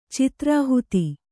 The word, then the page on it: ಚಿತ್ರಾಹುತಿ: Kannada